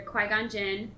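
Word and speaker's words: Qui-Gon Jinn: So it's when he's under Qui Gon Jinn. That's how you say it, right? – Yes.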